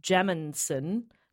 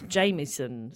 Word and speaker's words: Jamieson: Says, I've searched it before and Matt always pronounces it like Jaminson, but it's Jamieson.